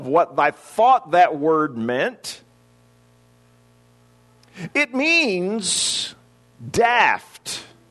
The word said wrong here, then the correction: deft: deft is the way that's pronounced.